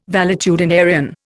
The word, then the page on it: valetudinarian: Indian